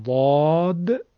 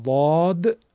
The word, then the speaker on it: ضاد: حرف ضاد رو در مصر و اطرافش مثل دال درش میگن، دا. و همین حرف رو در طرف های تونس و مراکش مردمش حرف طا تلفظ میکنن، مثل تا.